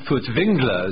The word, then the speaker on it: Furtwängler: Well, Wilhelm Furtwängler, as he should probably be pronounced, because there's an umlaut on the A in his name, was a great Germanic conductor of particular...